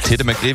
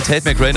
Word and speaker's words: Tate McRae: Ja, das ist die norddeutsche Bezeichnung. So spricht man sie in Nordfriesland aus. – Richtig. – Da heißt sie Tete McRae. Ansonsten heißt sie Tate McRae natürlich.